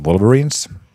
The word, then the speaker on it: Wolverines: Ja tosiaan tuossa äsken käytiin läpi jo teidän tulevaa sunnuntain vastustaja, Helsingin Wolverines. Saako se ääntä tolleen, Riikka? – No tuo taitaa olla se, mitä he itse toivoo, että se äännetään.